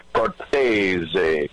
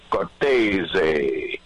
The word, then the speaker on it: Cortese: Well, like you said, old school Cortez, but I went straight to the horse's mouth one day and I said to my dad, how do we pronounce our last name? He said, Cortese, Cortese.